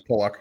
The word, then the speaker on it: Pulock: But also Ryan, you know, the Dauphin crowd is going to see, I always say Ryan Pulock, but I think I've even said it to his face and I might be wrong. Is that the pronunciation? I'm not a radio guy. – Yeah, it's Pulock.